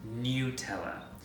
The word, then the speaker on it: Nutella: On the company's website, they say that it is pronounced Nutella, which to me drives me nuts, because if it's hazelnuts, it should be like Nutella. But they say it's Nutella.